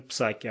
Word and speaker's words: Псаки: Вот, это все очень э, интересно и завуалированно подавалось сегодня на а, брифинге а, в Белом доме в исполнении Джен Псаки. А вы знаете, что, между прочим, Джен Псаки, фамилия, фамилия, первая буква «П» не читается,